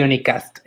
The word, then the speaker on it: Unicast: Sí, de Unicast. Es como de unicornio y podcast junto. Unicast.